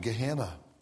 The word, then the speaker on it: Gehenna: In the New Testament, one of the words translated hell is pronounced in the original language, Gehenna.